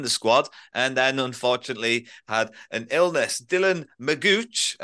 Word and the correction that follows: McGeoch: obviously, Liam, you can say it properly, can't you? – Dylan McGeoch.